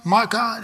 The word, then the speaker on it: Micah: Micah. I'm pronouncing it right. Micah. That's it. M-I-C-F.